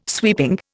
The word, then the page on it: sweeping: Indian